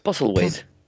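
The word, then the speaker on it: Postlethwaite: Pete Postletwaite. – Postelwaite. – Voilà, Postle Kun je het schrijven? Ja, Postle, W-A-I-T-H-E.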